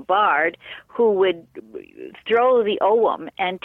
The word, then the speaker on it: Ogham: Now they had their own type of runes, and it was called the Ogham, and the Ogham or Oum, as they sometimes pronounce it,